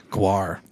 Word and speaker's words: GWAR: I did proceed to tell them that I went to a Gwar concert too.